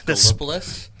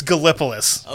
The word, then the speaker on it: Gallipolis: Gallipolis? – Gallipolis. That is the correct pronunciation. – Okay. I was like, not Gallipoli or... – A lot of people would have pronounced it Gallipolis. It's Gallipolis.